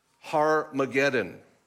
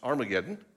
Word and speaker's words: Har-Mageddon: And they gathered them together to the place which in Hebrew is called Har-Mageddon, often pronounced Armageddon, right?